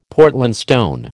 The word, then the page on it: Portland stone: American English